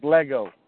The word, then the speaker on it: Lagow: Hey, that quarterback for Indiana, his last name, it's spelled L A G O W, but it's pronounced Lego. That's how you pronounce it, Lego.